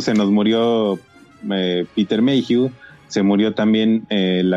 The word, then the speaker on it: Mayhew: Mayhew,